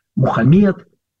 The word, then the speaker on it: Мухаммед: Допустим, Мухаммад, разные тоже произношения. Мухаммед – это великий пророк.